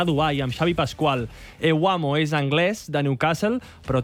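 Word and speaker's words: Evbuomwan: I sobretot, de cognom bastant impronunciable, no? – Tosan Ewamo. – Ewamo.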